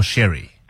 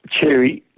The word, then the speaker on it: Chery: Is it Cherry or Sherry? – It's Cherry, like you'd say for cherry is the fruit.